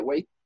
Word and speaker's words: Waithe: That's how you say it, Waithe? Yeah.